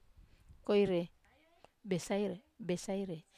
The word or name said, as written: koire besaire